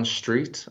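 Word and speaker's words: Streete: My name is Leon Street. And on the end of street, there's a silent E.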